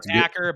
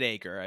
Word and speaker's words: Aker: He looks like Acker, but they pronounce it Aker, I believe.